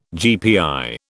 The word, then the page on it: GPI: British English